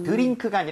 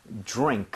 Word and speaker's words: drink: 그래서 드링크가 아니라 입모양 좀 모아서 drink 이렇게 가는 게더 자연스럽죠.